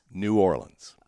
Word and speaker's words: New Orleans: Is it New Orleans or New Orleans? – New Orleans. – Okay, there we go. Everybody out there, it's New Orleans.